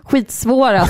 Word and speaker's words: skitsvåra: Men när man säger att Demon Souls och Bloodborne är skitsvåra spel, så är det betoning på skit. – Jaha, okej. Okay, mm. Jag vill men bara det säga det. Nyckelordet är skit.